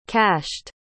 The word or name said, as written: cashed